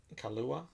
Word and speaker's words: Kaluuya: Daniel Aleiwo or whatever? – The guy from... – The lead in Get Out. – I can't pronounce these things. – Daniel... – Kaluuya? – Oh, Kaluuya? Who's Aleiwo? Who the fuck was I saying? – Okoye!